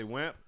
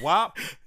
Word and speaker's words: WAP: WAP. – WAP? – WAP. WAP. – What I say, WAMP? Motherfuckers kept telling me, nigga, ain't no M in there. – Yeah, it's not. – Yeah, so it's WAP? – Yeah, it's WAP.